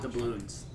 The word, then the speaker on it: doubloons: with the Dublons. Well, now they're talking... The albatross? – Not the albatross, the one, okay. The seahorse. – Doubloons. They're it's pronounced doubloons.